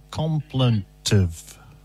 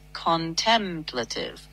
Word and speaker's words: contemplative: I'm saying contemplative and you're saying complentive. – Checking that now. You're right. It's contemplative, usually pronounced contemplative, four syllables with the stress on tem.